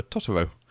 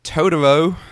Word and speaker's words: Totoro: I tried it once to watch the dubbed version of My Neighbor Totoro, which is pronounced exactly like that, My Neighbor Totoro. But in the American dub, it's Totoro.